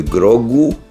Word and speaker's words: grog: Por exemplo, se pronuncia grogu ou grog em crioulo,